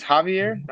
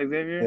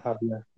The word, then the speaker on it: Xavier: And you said it's Javier, not Xavier? – Yeah, Javier. – George? – Is it spelled with a J? – Okay. – No, it's spelled with a... with an X.